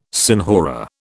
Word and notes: senhora: British English

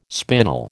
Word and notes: spinal: American English

